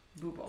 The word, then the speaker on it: Bubo: B-U-B-O. Bubo.